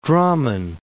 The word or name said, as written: Drammen